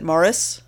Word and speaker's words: Maurice: The reason that you can't tell is when they rarely say his first name on the British show, but when they do, they pronounce it Morris.